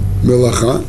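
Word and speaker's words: Мелаха (מלאכה): Само слово Малаха оно пишется вот таким образом. Это мем. Мем ламед алеф кав эй. Мелаха. И это слово, оно общее, имеет общее со словом малах.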